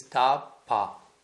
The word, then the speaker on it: tapa: which is ta-pa. Two syllables, ta-pa.